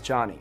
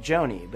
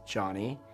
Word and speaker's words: Jony: For example, my name is Johnny, spelled J-O-N-Y, and it's normal for someone to pronounce my name like Joni,